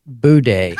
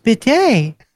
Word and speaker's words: bidet: She says, and next to the regular toilet is a bidet, except she didn't say bidet. She pronounced it boudet.